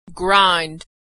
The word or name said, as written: grind